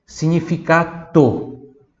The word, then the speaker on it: significato: que em italiano é com T, em português é com D e a pronúncia é diferente, né? Em português eu vou falar significado, em italiano significato. Significato com T lá no final, né? E o GN que tem som de NH.